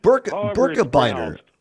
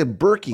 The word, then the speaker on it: Birkebeiner: Hey, by the way, I wanted to ask you, how do you pronounce it? The Birkebeiner? The Birkebeiner? – Birkebeiner. Yeah. Okay. I assume it's Swedish or Norwegian. Pardon me, Norwegian. – Okay. Yeah. Oh, okay. – So yeah, Birkebeiner. Some people say Berkey. Oh. I thought it was Birkebeiner, but I think most people here pronounce it Birkebeiner.